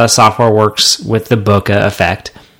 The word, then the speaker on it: Broca: Like, so they're working on the Broca. Is that it? Am I saying it right? – Yeah. – Is it Chewbacca or Chewbacca? – It's the Broca effect.